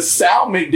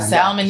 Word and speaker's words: salmon: The salmon dinner, that's probably how he pronounced it. Continue. – How you should say it? – Salmon. You don't pronounce the L in salmon. Salmon.